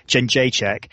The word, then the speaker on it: Jędrzejczyk: But to be honest, the big fight I wanted to see all weekend was the strawweight title fight between Joanna Jędrzejczyk and Claudia Gadela.